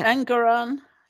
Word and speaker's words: Engoron: Engoron, is that how you say it? – Engoron.